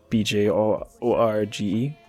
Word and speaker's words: Bjorge: And there was also the uh, the translator's name, uh, Gary Bjorge. I wasn't sure how to say his last name, Bjorge, B J O R G E. – But yeah, it's Bjorge.